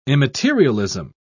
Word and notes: immaterialism: British English

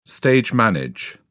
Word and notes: stage manage: British English

